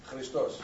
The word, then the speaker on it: Christos: Christos. – Christos, okay. I got too many Greeks in here. I've got to shape up my act here. It's translated Christos, and we call him the Messiah.